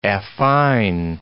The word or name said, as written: affine